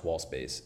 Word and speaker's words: Wxllspace: So this is a company called Wxllspace, but the A in wall is actually an X. So that's how you spell it. It's a little weird, but it is pronounced Wxllspace.